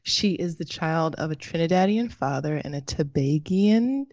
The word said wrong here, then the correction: Tobagonian: Am I saying that correctly? – Tobagonian. – Ah! This is the place where we learn. Tobagonian.